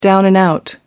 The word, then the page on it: down-and-out: American English